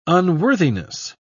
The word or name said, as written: unworthiness